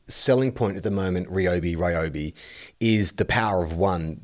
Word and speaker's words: Ryobi: Ryobi is how it's pronounced. Podcast Mike has put that up on the little message window. And it says, it's Ryobi, source, I can read Japanese.